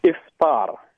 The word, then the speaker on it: Iftar: Iftar. – Iftar.